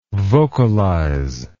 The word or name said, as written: vocalise